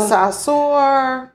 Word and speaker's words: Saussure: De Saussure. De Sausage. – Can you please spell it? – D-E. – Uh-huh. – That's the D. – Uh-huh. – S-A-U-S-S-U-R-E. Saussure. – Okay. – Saussure? Saussure.